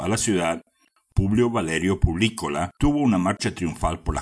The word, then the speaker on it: Publícola: Yo decía Publícola, pero en realidad se dice Publícola, con un acento en la letra I, y eso proviene del latín servicio al público.